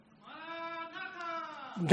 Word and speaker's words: Maranatha: Maranatha.